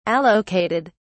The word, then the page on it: allocated: British English